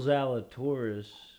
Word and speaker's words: Zalatoris: Open, Will Zalatoris, you know, rhyming with our favorite female body part, as we pointed out before, but he just has had a great year.